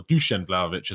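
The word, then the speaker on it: Dušan Vlahović: Dusan Vlahovic, or Dusan Vlahovic, as I'm told is better pronunciation.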